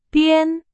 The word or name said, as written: 编